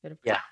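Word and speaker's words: Vihtavuori: And as you recently said, I've had now some experience with Berger bullets, Lapua casings, and the Vitavori. Am I saying that right? – Yep. Vitavori.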